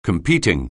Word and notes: competing: anglais

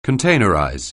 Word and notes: containerize: anglais

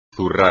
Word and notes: zurrar: espagnol

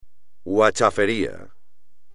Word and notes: huachafería: espagnol

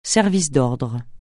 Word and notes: service d'ordre: francais

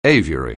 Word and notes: aviary: anglais

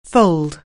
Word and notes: fold: anglais

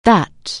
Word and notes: that: anglais

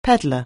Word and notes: peddler: anglais

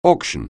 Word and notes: auction: anglais

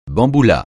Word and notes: bamboula: francais

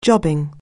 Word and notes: job: anglais

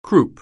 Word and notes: croup: anglais

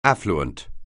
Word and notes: affluent: anglais

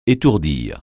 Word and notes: étourdir: français

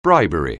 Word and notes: bribery: anglais

